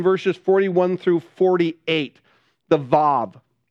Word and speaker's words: Vav: And so I know your Bible say W-A-W. The W should be a V sound in the Hebrew. It should be Vav. You can pronounce it V-A-V or V-A-H-V, Vav. And so the A is a little bit dragged out.